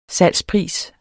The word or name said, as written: salgspris